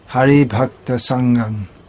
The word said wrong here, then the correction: संघान: हरि नहीं है, हरि भक्त संघान, संघान।